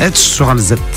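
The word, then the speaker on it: Esch-sur-Alzette: le 4 à Esch sur Alzette. J'espère que je prononce bien. – Esch sur Alzette.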